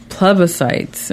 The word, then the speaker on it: plebiscites: We heard the term plebiscites. Am I saying that right? – Yep.